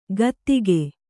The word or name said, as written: ಕಾತಿಕೆ